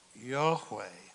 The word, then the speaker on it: YHWH: How do you pronounce YHWH? Yahweh. And then we insert the vowels because it's easier to say Jehovah.